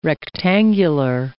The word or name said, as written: rectangular